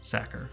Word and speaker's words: Sachar: I did double check to make sure, because I had in my head that it was Sacker, but I was doubting if that memory was based upon something real, or if I just thought that it sounded better than pronouncing it with a CH sound, but it is Sacker.